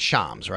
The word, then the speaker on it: Shams: Is it Shams? It's not Shams. It's Shams, right? Shams Charania. – Yep.